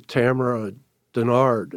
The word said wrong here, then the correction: Tamaya Dinard: did I pronounce it right? – Tamaya Dinard, yes.